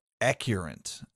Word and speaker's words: Accurint: There's this database called Accurint, and I'll spell it for you. It's A-C-C-U-R-I-N-T, which is a law enforcement database that provides phone numbers and social media accounts, and it's supposed to be used for police purposes.